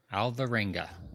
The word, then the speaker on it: Alvarenga: So Carlos, how do we say your last name? – Exactly how it's written. Alvarenga. – Alvarenga.